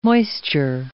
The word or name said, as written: moisture